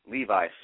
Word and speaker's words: Levi: Your middle name, is it pronounced Levi or Levy? – Levi. – Levi.